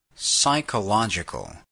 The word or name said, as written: psychological